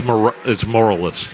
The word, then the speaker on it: Morales: I believe you're mispronouncing it. – Understatement of the years. – It's moralless. – Moralless. – Yeah. – Moralless.